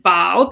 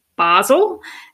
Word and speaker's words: Basel: Now, when you you pronounced it Bâle, because that's the French pronunciation. I think most English speakers probably pronounce it Basel.